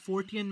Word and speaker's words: Fortean: Also, the idea of the study of the Fortin, am I pronouncing that right? – Can you spell it? – No, I'm not very good. – So, the Fortean magazine? – Yes.